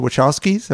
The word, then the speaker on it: Wachowskis: And Jeff Darrow ended up doing work for the Wachowskis. Am I saying that correctly? Yes, he helped design Speed Racer and things like that.